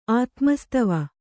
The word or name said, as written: ಆತ್ಮಸ್ತವ